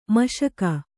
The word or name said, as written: ಮುಷ್ಕ